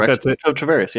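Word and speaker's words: Travaris: I can never pronounce his first name correctly. – That's it. Tarveris, yeah.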